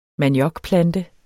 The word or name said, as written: maniokplante